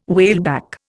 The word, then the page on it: whaleback: Indian